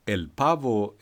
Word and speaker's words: el pavo: El pavo. Men okej. Uttalas så då? – Det uttalas så. El pavo esta triste.